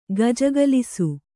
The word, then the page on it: ಗೆಜ್ಜೆಗೊಲಸು: gejjegolasu